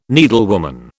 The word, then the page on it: needlewoman: British English